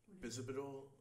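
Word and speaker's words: Bisabolol: Am I saying that right? Bisabidol? – Oh, yeah, Bisabidol.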